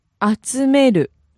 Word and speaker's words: 集める: The difference between the transitive, which was 集める, and the intransitive, 集まる, is the third syllable.